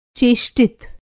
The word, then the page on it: चेष्टित: Hindi